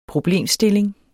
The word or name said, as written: problemstilling